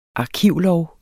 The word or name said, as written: arkivlov